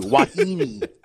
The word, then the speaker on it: Wahine: Well, don't they pronounce Wahine wrong? Like I might sometimes. – They say it like you do, Wahine. Even the mainlanders get that right nowadays.